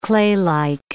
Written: claylike

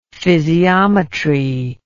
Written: physiometry

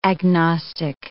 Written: agnostic

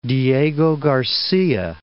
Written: Diego Garcia

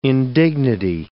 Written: indignity

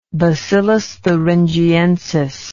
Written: Bacillus thuringiensis

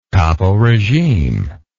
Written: caporegime

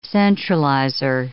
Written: centralizer

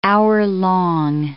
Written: hour-long

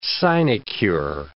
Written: sinecure

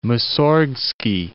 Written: Mussorgsky

